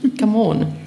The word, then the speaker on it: Gamon: Ähm, nein, aber meinen Namen spricht man anders aus, nämlich Gamon. Das ähm, muss ich bei jeder Gelegenheit dazu sagen.